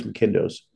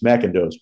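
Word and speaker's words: McIndoe: And Matthew Broderick and Nathan Lane and Mel Brooks bought the restaurant right next door called Angus McKindo's. Or as Richard Kind tells me, I'm mispronouncing it, it's McKindo's, but one of the names.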